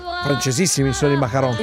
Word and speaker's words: macaron: E so, senti anche la pronuncia che è. – Macaron. – Macaron. Con la R deve finire.